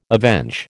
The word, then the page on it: avenge: American English